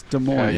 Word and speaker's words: Des Moines: I don't think you pronounce the S. – It's Des Moines. – Yeah, you don't. It's Des Moines. – You don't say the S.